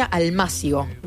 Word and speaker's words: almácigo: Me corregían que se pronuncia almácigo.